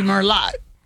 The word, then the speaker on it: Merlot: It would be Merlot. It says Merlot. – I guess so. – Yeah, remember Merlot is, and that T is silent on the end of Merlot.